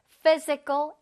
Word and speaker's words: physical: 자, ph가 있어. 그래서 p 소리가 날것 같지만 아니다. ph가 같이 붙으면 f 소리가 나요. f 소리가 나요. 그래서 physical, 그 다음에 education.